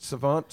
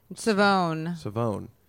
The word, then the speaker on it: Savone: On Cara Savant and is it Savant? – Savone. – Savone.